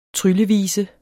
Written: tryllevise

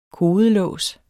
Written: kodelås

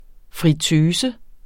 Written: friteuse

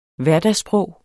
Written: hverdagssprog

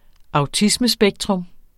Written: autismespektret

